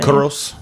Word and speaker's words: Kouros: Korus. How you say it? – I'm still guessing, just like you. – KOUROS. – Mm-hmm. So 2018 Kuros. From Nemea. – Kuros.